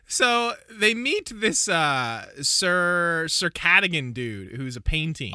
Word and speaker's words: Cadogan: Not, I pronounced it Cadogan. It's Cadogan. – Cadogan. – Like a toboggan.